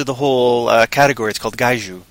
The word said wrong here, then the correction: kaiju: Or I'm probably manhandling the pronunciation of the word, but then... I don't care. I can live with it. Kaiju. Sorry, yeah, Kaiju.